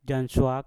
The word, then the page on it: janswak: Mpur